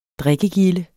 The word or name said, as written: drikkegilde